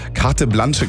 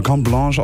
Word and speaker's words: Carte Blanche: Es wird Carte Blanche geschrieben, aber Carte Blanche ausgesprochen. Die Kernbotschaft von diesem Track ist Dankbarkeit.